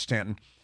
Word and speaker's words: Staunton: I should point out that Stanton, Virginia, is spelled Staunton, S-T-A-U-N-T-O-N, but it is pronounced Stanton.